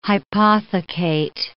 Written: hypothecate

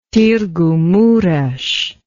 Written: Targu Mures

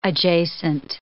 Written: adjacent

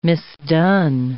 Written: misdone

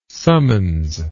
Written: summons

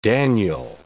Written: Daniel

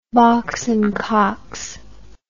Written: box and cox

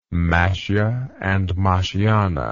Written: Mashye and Mashyane

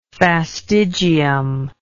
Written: fastigium

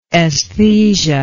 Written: esthesia

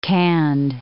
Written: canned

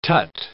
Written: tut

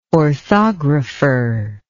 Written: orthographer

